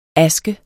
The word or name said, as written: aske